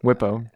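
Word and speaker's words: Bwipo: The B is Whippo, guys. Whippo.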